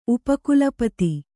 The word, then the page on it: ಉಪಕುಲಪತಿ: Kannada